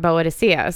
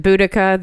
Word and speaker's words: Boudica: And I've listened to a few of their episodes, but their first episode, they talk about Boa Dicea. So I wanted to, they pronounce it Boudica. There are different ways to pronounce it. Okay. – But I've always heard Boa Dicea.